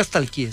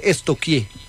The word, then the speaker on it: stalkear: Porque no se dice nunca stalkeé, se dice estoquié.